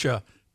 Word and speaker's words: kombucha: It's kombucha. And am I saying that right? – Kombucha. – Kombucha, yes.